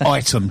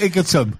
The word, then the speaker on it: Egerton: It's item. But it's spelled Egerton.